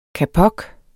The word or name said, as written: kapok